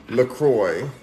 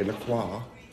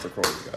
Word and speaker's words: LaCroix: But we're going to drink this with some LaCroix, since that's how you pronounce it, apparently. – That's right. – People don't want to pronounce it the fancy way, LaCroix. But whatever. Whatever. Yeah, it's going to be boring. It's really pronounced LaCroix, guys.